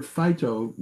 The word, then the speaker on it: phyto: It's a way, the word phyto is spelled P-H-Y-T-O, plants, what it means, but it actually is pronounced like the word fight.